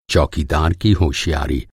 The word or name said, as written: होशियारी